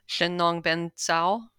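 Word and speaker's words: Shennong Ben Cao: Yeah, and I think, if I remember the name right, so the earliest book of Chinese medicine pharmacopoeia was the, I may pronounce this poorly, Shennong Ben Zhao? – Yes.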